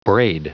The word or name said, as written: braid